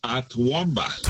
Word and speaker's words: Toowoomba: Toowoomba.